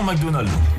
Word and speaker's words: McDonald's: Ligue 1 McDonald's.